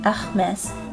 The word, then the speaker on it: Hermès: 这个品牌名字也是很容易读错的，因为呢，它也是一个法语词，字母 H 在法语中是不发音的，所以呢，应该是 Hermes，Hermes。同样，R 是小舌音，有一个类似的音，记住要把声音往喉腔中间压一压哦。 Hermes。